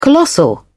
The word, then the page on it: colossal: British English